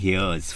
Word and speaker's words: hirs: Hr, which is H-I-R. And Hrs. Which is H-I-R-S, which is actually, sorry, it's pronounced here and here's for the non-binary identifier.